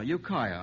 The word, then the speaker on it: Ukiah: Jesse Duell of Ukiah, I hope I'm pronouncing that correctly, California. I certainly am pronouncing that correctly. Ukiah, I'm informed, sends this one in.